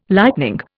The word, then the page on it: lightning: Indian